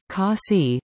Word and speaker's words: Casa: The name Casa, pronounced Kasi, means care in Indonesian, Masood's mother tongue.